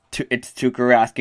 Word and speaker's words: Tuukka Rask: And then my third key, and you said it correctly, it's Tuukka Rask.